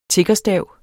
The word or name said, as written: tiggerstav